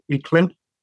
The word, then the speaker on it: Aichlinn: The way I pronounce it is Aichlinn, and that's based off my dad's pronunciation.